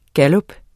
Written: gallup

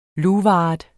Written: luvart

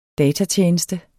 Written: datatjeneste

